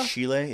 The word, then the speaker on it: Schiele: I guess it's pronounced Schiele. – Schiele, yeah. S-C-H-I-E-L-E.